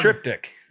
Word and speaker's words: Triptych: Um, Demon Hunter's the Tricepta, tri. Um, you know what one I'm the talking. Triptych. – Yeah, the triptych.